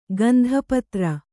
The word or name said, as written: ಗಂಧಪತ್ರ